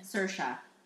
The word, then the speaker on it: Saoirse: Saoirse. – Saoirse. I'm bouncing between her and Margot. – Okay. – Probably Saoirse.